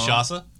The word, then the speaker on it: Kinshasa: I probably pronounced that Kinshasa. – Wrong. – Kinshasa. There you go.